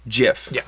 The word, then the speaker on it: GIF: He actually did come out in an interview and say it's pronounced GIF, like the peanut butter.